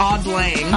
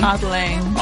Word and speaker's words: Auld Lang: Auld long? – No. – No? How do you say it? – Auld lang. – Auld lang. – Auld lang. – Auld lang. Like the song that they play on New Year's.